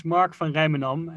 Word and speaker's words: Mark van Rijmenam: My full name is Mark van Rijmenam, and that's a typical Dutch name.